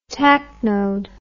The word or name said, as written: tacnode